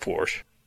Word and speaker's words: Porsche: It's pronounced Porsche.